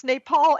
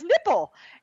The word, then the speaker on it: Nepal: At one meeting, Trump allegedly mispronounced Nepal as nipple and Bhutan as button, two sources told Politico.